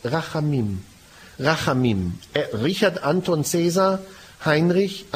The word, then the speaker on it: Rachamim: Rachamim. Alle. – Rachamim.